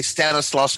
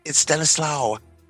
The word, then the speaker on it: Stanislaus: but when you're born in a state, But then again, also Luis can get on folks for mispronouncing Stanislaus. You know, some folks will say Stanislaus County. No, it's Stanislaus.